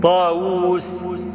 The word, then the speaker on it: طاووس: ما هو سيد داود احنا بنكتبها بواو واحده، وهي بتتنطق او بالاصل تتنطق باتنين واو. لما تقول داود دال الف واو واو دال، طاووس ط الف واو واو سين، ها؟ لكن احنا بنكتبها داود طاووس بواو واحده.